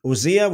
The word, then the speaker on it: Uzziah: Now, all the people of Judah took Uzziah, as a lot of people would say. Uzziah would be the more of the correct pronunciation.